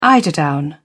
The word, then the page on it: eiderdown: British English